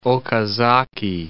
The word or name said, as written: Okazaki